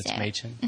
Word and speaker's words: Mädchen: Machen.